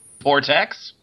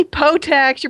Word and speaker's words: Portaxx: Vortex. – It's actually Potex. You're pronouncing it wrong.